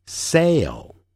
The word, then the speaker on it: sail: 第六组，航行 sail sail，那个 L 这个音标符号在字尾的时候要念成欧洲的 O，但舌尖抵上去 O。